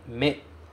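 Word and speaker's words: Me (目): É o kanji me. Eu vou colocar ele aqui para a gente dar uma olhada como é que ele é. Me. Este kanji se pronuncia me e ele quer dizer olho.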